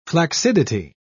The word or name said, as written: flaccidity